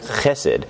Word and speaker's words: chesed: You could put a ch if you want to get that guttural in there, chesed. Or you could just, spell it H-E-S-E-D.